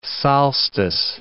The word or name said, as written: solstice